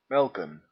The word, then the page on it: melken: /mɛlkə(n)/; /mɛl.kə:/